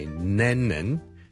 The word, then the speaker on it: Lennon: 第一个字咧就系、是、人名嚟嘅，John Lennon。 John Lennon。 John Lennon 一个英国嘅好出名嘅歌手作曲家啦。 Lennon。 L-E-N-N-O-N。第一個是 L 音，所以不要將它讀成 N 音，不是 Lennon，是 Lennon， Len， Le Le Le Le Le Le Le Le Le Le Le Le Len，後面那個才是 N 音，